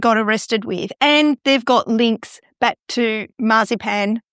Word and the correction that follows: Mazepin: Did I say that right? – Mazepin. – Whose father has links to Vladimir Putin. – He's an oligarch. – Mm. Yeah, which is why Mazepin got dropped.